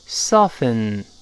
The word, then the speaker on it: soften: 那但注意不要读 soften，要读成，跟老师读一遍。 soften, soften，哎，这个特别是女同学啊，这个词要读对。